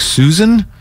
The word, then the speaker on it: Suisun: Whitey, Suisun City is pronounced like Susan. I'm from Vallejo. I thought it was Suisun.